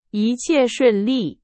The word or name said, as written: 一切